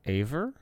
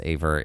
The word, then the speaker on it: aver: What is rave and aver? – So I thought it was pronounced aver. It is apparently pronounced aver, and it is a verb, and it means to assert to be the case.